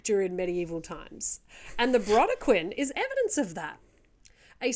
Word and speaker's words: Brodequin: The next is, it's spelled BRODEQUIN. I'm going to say Brodequin. Would you say that? – That sounds lovely. – We'll go with Brodequin.